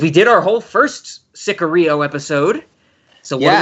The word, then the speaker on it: Sicario: It is Sicario.